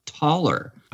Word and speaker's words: Thaler: it's a Scandinavian name. It's pronounced taller. Right. And a taller, the way he spells it, actually, T-H-A-L-E-R, was a silver coin between the 14th and 18th centuries.